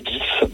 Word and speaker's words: Gies: Alors moi je le dis Gis, mais Gis. C'est vrai que on peut le dire de différentes façons.